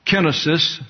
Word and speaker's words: kenosis: The derivative of that verb is kenosis, pronounced in the English as kenosis and in your English Bibles as such.